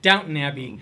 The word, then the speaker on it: Downton Abbey: Downtown Abbey. – Yeah, Downtown Abbey. – Yeah, right. Downton Abbey. – Downton Abbey.